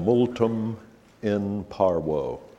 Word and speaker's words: multum in parvo: That's the way it's properly pronounced in Latin. Multum in parvo, much in little. And that's exactly what Hosea is, a multum in parvo. V in Latin was always pronounced like a W in English.